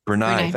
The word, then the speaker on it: Brunei: Brunei.